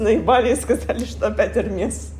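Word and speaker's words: Hermès: Да, только там S не произносится, произносится. Потому что это французское слово. Хочешь сейчас ход конем, просто на который делится жизнь до и после? Это его фамилия, и сам а, его там представитель главный есть интервью, в котором озвучивается вслух его фамилия с «С». А по правильно по правилам, да, мы «С» не читаем, но так как это фамилия, она там читается у него. Это ну, Эрмес, да.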